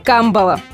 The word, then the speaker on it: камбала: Итак, рыба камбала. Многие ошибочно ставят ударение на последний слог, а этого делать не нужно. Название рыбы камбала пришло в русский язык из финского, а в языке-источнике ударение падает на первый слог.